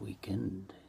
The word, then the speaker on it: Weeknd: So there's a rapper, for example, called Weeknd. You know, but it's pronounced weekend.